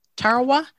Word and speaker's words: Tarawa: Maybe you can help me with this pronunciation. Tarawa. Tarawa? – Yes, that's the name of a battle in World War II, right? – You got it.